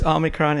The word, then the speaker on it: Omicron: How do you pronounce it? You know better than me. – Yeah, it's another Greek word. Yeah. It's Omicron indeed.